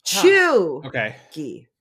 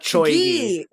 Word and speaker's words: cheugy: It is C-H-E-U-G-Y. Chuggy. I had to look up the syllable emphasis. Chuggy. Not choy-gy.